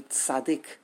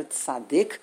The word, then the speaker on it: tzaddi: And that is probably why it is our tradition to call the letter tzaddik. But if you look carefully in the Rishonim, they tell us that the real pronunciation of this letter is tzaddi.